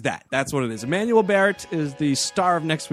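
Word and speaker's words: Emmanuelle Béart: Emmanuel Baird.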